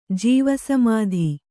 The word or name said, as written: ಜೀವಸಮಾಧಿ